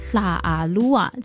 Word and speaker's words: 拉阿鲁哇: 讲到拉鲁哇族呢，其实这个汉字叫拉阿鲁哇，但是呢他们的发音并不是叫拉阿鲁哇，而是叫拉阿鲁哇，就是它是用一个有点舌尖音去发音的。